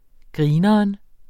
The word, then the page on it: grineren: ˈgʁiːnʌʌn